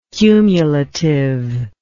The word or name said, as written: cumulative